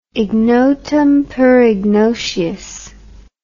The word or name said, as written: ignotum per ignotius